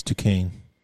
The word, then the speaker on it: Duquesne: But next they play Duchesne, which is spelled Duquesne. – It's Duquesne. – Is it Duquesne? – Yeah.